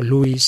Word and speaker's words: Lewis: Nos estamos refiriendo al escritor y filósofo inglés converso, del, del ateísmo al cristianismo, C.S. Lewis, si leemos en castellano, o Lewis, si lo pronunciamos en inglés.